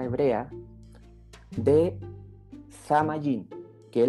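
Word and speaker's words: Samayin (שמים): Ahora, cielo en el hebreo se pronuncia Samayin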